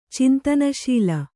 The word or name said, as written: ಚಿಂತನಶೀಲ